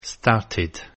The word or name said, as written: started